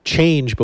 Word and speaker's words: Chang'e: Now, from what we found is that Chang is supposed to be pronounced like change, but with an H, Chang. And the E is like in her, but without the R. So that's Chang, something like that.